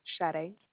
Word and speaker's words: Sade: Sade. It's spelled S-A-D-E.